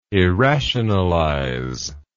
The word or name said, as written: irrationalize